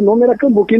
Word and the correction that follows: cumbuca: e não é cambuca. – É cumbuca. – Correto? É cumbuca, cara.